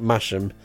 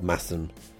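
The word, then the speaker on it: Masham: Anyway, we're having this massive do at a village in a nearby village called Massam, which is spelt Mash'em, and I pronounce it Mash'em every time I say it. It just drives Mum mad. She corrects me every time. As if I don't know it's supposed to be called Massam.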